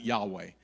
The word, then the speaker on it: Yahweh: Like we, we pronounce it Yahweh.